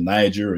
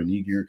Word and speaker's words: Niger: We can go to Acts chapter 13, where we're talking about Simon, the, you know, the Niger or Niger, you know, depending on how people want to pronounce that,